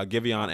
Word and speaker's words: Giveon: I've been listening to Giveon a decent amount. I think that's how you say his name. – Yeah, it is.